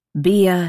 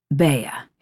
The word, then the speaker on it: Bea: Bia, he says, pronouncing my name with a long E. – It's pronounced Bea.